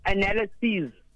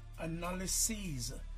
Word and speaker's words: analyses: What is the plural, guys, of analysis, and how should the answer be pronounced as well? – And analyses. – Say it again. – Analyses. – Versus? – Sorry? – Versus what? – Analysis. – Singular being analysis and plural being? – Analyses.